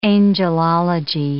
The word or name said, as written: angelology